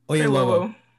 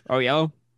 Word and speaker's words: Oyelowo: I said that right? Close enough. – Oyelowo. – No, not even gonna. – The white man in our podcast here, has decided to Oh yellow? – No, no, no.